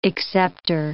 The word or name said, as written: accepter